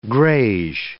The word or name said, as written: greige